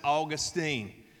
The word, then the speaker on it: Augustine: First one is a guy named Augustine, or some people pronounce it Augustine.